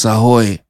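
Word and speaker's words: ahoj: She just taught me how you say properly in Slovak. It's ahoy. All right, pronounces ahoy. All right, so not aho, it's ahoy.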